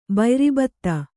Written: ಬಯ್ರಿಬತ್ತ

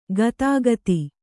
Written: ಗದುಗದ

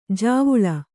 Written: ಜಾವುಳ

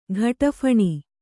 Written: ಘಟಫಣಿ

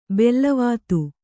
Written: ಬಿಲ್ಲವತ್ತೆ

